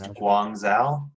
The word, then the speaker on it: Guangzhou: I, I think that's pronounced Guangzhou. – Is that what it is? Okay. – Something like that.